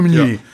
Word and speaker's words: Meunier: Pinot Meunier. – Ja, Meunier. M-E-U-N-I-E-R. – Ach, Meunier. – Meunier heißt ja, das heißt einfach Müller.